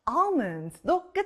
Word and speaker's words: almond: 아, 아몬드, almond도 끝에 and 소리가 나요.